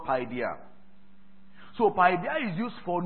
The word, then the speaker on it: paideia: Paideia is spelled P-A-I-D-E-I-A.